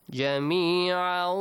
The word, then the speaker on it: جميعا: أرض ما في الأرض جميعا جميعا تنوين plus واو حسين. How do you say that? – جميعا. – جَمِيعًا. You extend the sound for two beats.